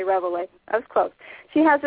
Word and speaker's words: Reveille: Yeah, it's Reveille, like that little Reveille. Ditty in the okay.